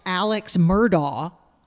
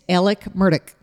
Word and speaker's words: Alex Murdaugh: And the first thing that's going to blow your mind is it is not pronounced Alex Murdaugh. It's Alec Murdaugh. – Alec Murdaugh. – Alec Murdaugh. He'd say, well, I'm Alec Murdaugh. – Alec Murdaugh. – And it's Murdaugh, not Murdaugh. Murdaugh.